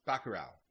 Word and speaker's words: Bacurau: Bacurau, am I saying that correctly? – uh, Bacurau. – Bacurau. Yeah. That's how they were saying it in the movie.